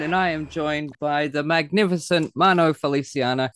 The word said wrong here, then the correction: Felciano: Felciano, sorry, I just added an I in there.